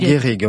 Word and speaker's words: Gehrig: Gehrig, comment vous prononcez ? – Moi, je pense qu'il faut dire Gehrig. – Gehrig, oui, aux éditions Romane.